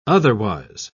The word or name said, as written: otherwise